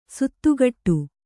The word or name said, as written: ಸಾತಗಡಿ